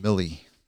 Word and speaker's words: Milley: Yeah, well, what's interesting is our military, General Milley, is that how you say it, Milley? – Milley.